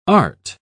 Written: art

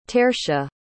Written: tertia

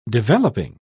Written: developing

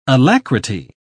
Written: alacrity

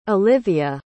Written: alivia

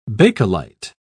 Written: bakelite

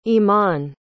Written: eman

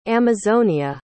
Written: amazonia

